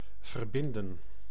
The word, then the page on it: attacher: France; [a.ta.ʃe]